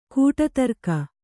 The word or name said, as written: ಕೂಟತರ್ಕ